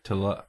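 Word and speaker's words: Talalay: And this was, this was, how would you say that name? Rachel Talele? – Talele.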